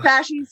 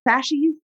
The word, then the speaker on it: fasces: Fasci spelled F-A-S-C-E-S. It's fasci. Sorry. It's pronounced fasci.